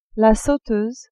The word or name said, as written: sauteuse